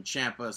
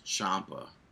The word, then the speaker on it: Ciampa: Yeah, but I'm pretty sure like the actual pronunciation of that is Ciampa. – That's what I thought too. But like the actual Italian pronunciation of Ciampa is Ciampa. – Yeah, but he says it rhymes with Tampa.